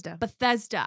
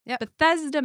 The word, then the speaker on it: Bethesda: Bethesda. – I don't like the T-H and the S next to each other. Bethesda, Maryland, which is where the incident occurred that we will talk about in a second.